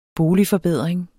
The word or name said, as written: boligforbedring